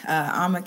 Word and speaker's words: Ama: You can find me at Ama Kessie at Twitter or on Instagram. – And how do you spell that? – A-M-A, and then the last name is K-E-S-S-I-E.